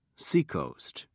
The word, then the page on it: seacoast: British English